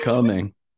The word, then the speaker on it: Cumming: Um, it spelled like it sounds coming.